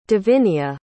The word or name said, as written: divinia